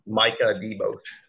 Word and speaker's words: Micah DeBose: Yeah, I feel like Demarcus Riddick and uh, Mika DeBose. Is that, my? am I saying that right now? – Micah DeBose.